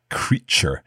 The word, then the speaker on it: creature: So we have this T-U-R-E at the end, but the T is not pronounced T, as in two. It's pronounced Ch, like in cheese. So we say creature.